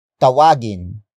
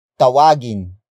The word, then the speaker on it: tawag: Word for word it's tawagin mo ang pusa. Tawagin mo ang pusa.